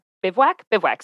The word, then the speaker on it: bivouac: And so I started really getting into those bivouac sessions, right? Am I right when I'm pronouncing that right? – Yeah. Bivouac.